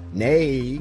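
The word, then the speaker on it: née: Nay, is that how you say it? I've always read it, like when you identify someone in their married name and then say nay, it's N-E apostrophe E, nay.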